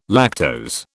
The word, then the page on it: lactose: British English